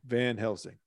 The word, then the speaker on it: Van Helsing: Yeah, when Keanu Reeves, I believe he played Van Helsing. I can't even pronounce it. – Van Helsing.